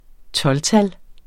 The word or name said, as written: 12-tal